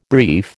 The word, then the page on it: brief: American English